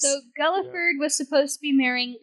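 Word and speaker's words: Guildford: So, John Dudley was attempting to have his son, and his son's name was, I don't really know if I'm going to pronounce this right, but, Gulliford? – Gulliford. – Yeah. Gulliford. – G-U-L-I-F-O-R-D.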